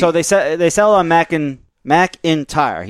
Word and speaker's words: McIntyre: For us, McIntyre is more like four syllables because the Y sound, McIntyre, is a diphthong.